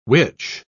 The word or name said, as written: witch